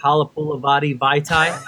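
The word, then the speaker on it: Halapoulivaati Vaitai: Halapulavati Vaitai.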